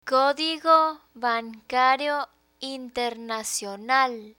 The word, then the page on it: codigo bancario internacional: spanish